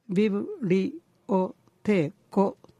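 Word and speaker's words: biblioteko: 音節の数はビブリオテーコと 5 つです。アクセントは後ろから 2 番目の母音の音節、つまり母音の位置に置きますので、ビブリオテーコとなります。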